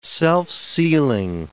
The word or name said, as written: self-sealing